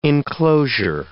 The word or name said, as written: inclosure